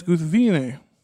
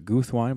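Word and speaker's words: Guthwinë: Yeah, the name, apparently the main issue with the name there is most people pronounce it Guthwine, but it's supposed to be, what, three syllables, right? – Three syllables, yep.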